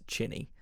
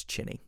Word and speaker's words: Qinni: Her name, her real name is Ching Han, and it's pronounced Chinny. So it is Q-I-N-N-I, but it's Chinny.